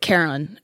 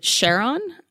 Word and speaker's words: Charon: So I pronounce it Charon because that's the name of basically it's derived from the mythology, the ferryman of the dead, the person who takes you over to Hades. – Right. – Hades being the Greek name for Pluto. But people like to pronounce it Charon.